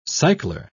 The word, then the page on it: cycler: British English